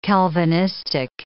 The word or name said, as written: Calvinistic